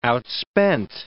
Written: outspent

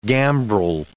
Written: gambrel

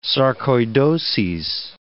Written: sarcoidoses

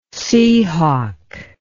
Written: seahawk

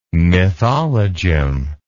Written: mythologem

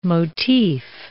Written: motif

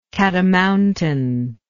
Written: catamountain

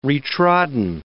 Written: re-trodden

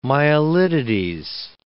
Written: myelitides

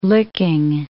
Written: Licking

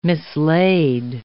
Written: mislaid

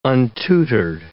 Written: untutored

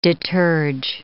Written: deterge

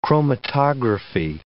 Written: chromatography

